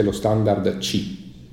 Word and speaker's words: Qi: Sì, tra l'altro si scrive QI, però si pronuncia C. Non si sa perché, non so se tu lo sai. – Si pronuncia C perché è cinese, ed è una parola cinese che vuol dire flusso di energia